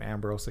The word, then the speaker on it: Ambrose: Or just search my name, Ambrose Ayala. A-M-B-R-O-S-E-A-Y-A-L-A. No one knows how to spell it.